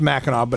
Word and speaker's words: Mackinac: It's not Mackinac with a W, it's Mackinac Bridge. – Yes. – You agree? – It's Mackinac Bridge. It's pronounced Mackinac, but it's spelled Mackinac.